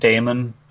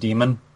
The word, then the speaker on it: daemon: Also, it irritates me because it's not pronounced daemon; it's pronounced demon.